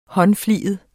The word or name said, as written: håndfliget